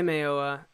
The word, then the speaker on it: Mayowa: Mayowa. – I say Mayowa. I think that's how broadcasters say it most of the time.